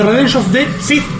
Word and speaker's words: Revenge of the Sith: Es el último día de rodaje de Revenge of the Sith.